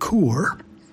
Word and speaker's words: Caoir: And sure enough, on the featured album, which is called C-A-O-I-R, pronounced Coor, by Joy Dunlop, today there actually is a song about a one-eyed giantess.